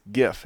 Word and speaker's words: GIF: I forget who posted it, but that it's GIF. It's pronounced with a soft G.